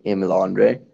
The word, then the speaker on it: Emil Andrae: In an American accent, I would say Emil Andrae.